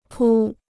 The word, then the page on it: 扑: pū